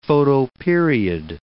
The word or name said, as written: photoperiod